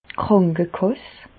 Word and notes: d' Krànkekàss: Bas Rhin; Reichshoffen

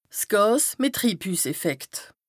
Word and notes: 's Gàs mìt Triibhüsseffekt: Bas-Rhin; Strasbourg

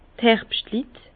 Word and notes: d' Herbschtlit: Bas-Rhin; Herrlisheim

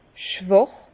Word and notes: schwàch: Bas-Rhin; Herrlisheim

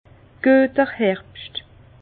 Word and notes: Güeter Herbscht: Bas Rhin; Herrlisheim

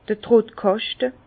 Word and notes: d'r Trottkàschte: Bas-Rhin; Herrlisheim

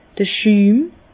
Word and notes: d'r Schüm: Bas Rhin; Herrlisheim

